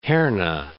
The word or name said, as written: Herne